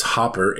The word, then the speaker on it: Hopper: I said, it's Hopper, H-O-P-P-E-R, as in grass.